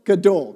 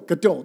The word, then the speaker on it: Gadol: I almost said gouda, but that's a cheese. I'm like, I don't think that's right. It can't be Gouda cheese. Like, that would be too much of a coincidence. Gouda, right? Saying it right? Gadol. Gadol. Dang it. Gadol.